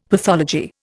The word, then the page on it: pathology: Indian